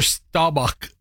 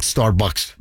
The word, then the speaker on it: Staubach: Yeah, it was maybe when Roger Staubach. Starbucks. – No. – Starbucks. – Oh, come on. You know it's not Starbucks.